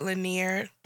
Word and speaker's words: Lanier: Lake Lanier.